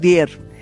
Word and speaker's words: Dyer: El doctor Dier. Se escribe D-Y-E-R.